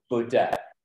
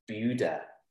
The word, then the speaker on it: Buda: There's a town going up to Austin, it's called Buddha, or I always thought, and then I was told off for saying Buddha. It's actually pronounced Buddha, B-U-D-A.